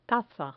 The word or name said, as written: taza